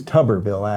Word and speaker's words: Tuberville: His name is Tuberville, actually, so I mispronounced it as well.